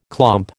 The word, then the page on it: clomp: American English